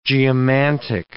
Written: geomantic

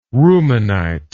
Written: rumanite

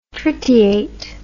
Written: tritiate

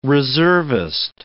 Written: reservist

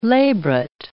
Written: labret

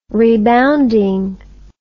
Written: rebounding

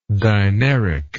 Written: dineric

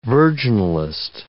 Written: virginalist

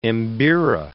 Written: mbira

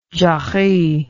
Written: jarry

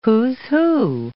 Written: who's who

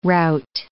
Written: rout

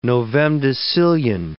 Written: novemdecillion

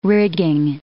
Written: rigging

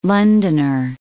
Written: Londoner

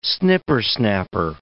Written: snippersnapper

